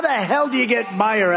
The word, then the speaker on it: Meijer: One of the 10 impeachers, a guy who spells his name M-E-I-J-E-R, but they pronounce it Meyer.